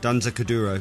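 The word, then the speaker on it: Danza Kuduro: I imagine on Stupid Street it's more like European songs, like Donze Kuduro, and is that how you say it? – Donze Kuduro. – That's the one.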